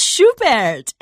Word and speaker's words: Schubert: and it's Schubert. Did I say it right? – It is Schubert, and yeah.